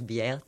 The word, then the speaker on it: Biert: Enfin, je prononce Biert, je ne sais pas, B-I-E-R-T. – Voilà, on va dire pareil, Biert.